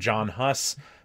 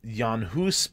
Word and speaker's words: Jan Hus: I use Jan Hus because that's actually his name, how it would have been pronounced,